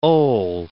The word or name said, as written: OLE